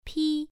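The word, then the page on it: pi: tone 1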